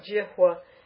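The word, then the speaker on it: jihvā: It's a H plus a V. Conjunct consonant, a H with a V. – And it's pronounced as a W? – Jihwa. Well, yeah, because the consonant V, when it's in a conjunct consonant, unless it's a semi-vowel, will take the pronunciation of wa. When the semi-vowel v is surrounded by vowels like vadi or avagachati, right? Ava or vadi, there's no conjunct consonant, then it takes the v sound.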